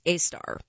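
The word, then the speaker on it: Sagittarius A*: The Global Array's other main target is the black hole at the center of the Milky Way, Sagittarius A, pronounced A-star.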